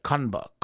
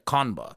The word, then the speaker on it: Kanba: K-A-N-B-A, Kanba, Kanba. And from what I understand, I believe it's a pun off of cucumber, kamba, kanba, I think is what it's going to be. I think is how you'd probably say it. Kanba, K-A-N-B-A, like cucumber.